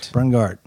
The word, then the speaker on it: Brunghart: Did I say it right? – Brunghart. – Okay. – Really exercise the G. – Brunghart.